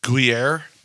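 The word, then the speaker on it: Gruyère: Gruyere? – Yep.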